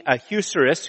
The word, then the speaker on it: Ahasuerus: Um, it, what, what happened last week is we were introduced to King uh, uh, Eucerus, which I didn't pronounce his name correctly. I have a hard time pronouncing his name. Uh, but it's about King Ahasuerus, uh, who's the emperor of Persia.